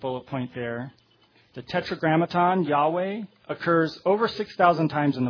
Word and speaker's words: Yahweh: Now, Yahweh is a little bit of a difficult one to define because we don't have all of the information on it, on the correct pronunciation, but the scholars do tend to lean towards Yahweh as the correct pronunciation.